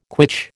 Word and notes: quitch: American English

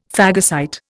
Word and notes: phagocyte: Indian

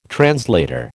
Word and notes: translator: American English

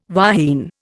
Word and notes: wahine: Indian

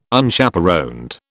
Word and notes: Unchaperoned: British English

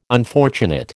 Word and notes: unfortunate: American English

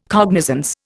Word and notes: cognizance: Indian

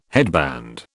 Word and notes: headband: British English

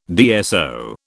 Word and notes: DSO: British English

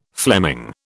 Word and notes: Fleming: British English

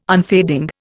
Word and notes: unfading: Indian

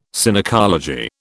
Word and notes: Synecology: British English

